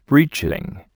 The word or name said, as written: breaching